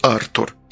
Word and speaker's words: Артур: Меня зовут Артур. Именно так, с ударением на первом слоге.